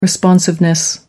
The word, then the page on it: responsiveness: American English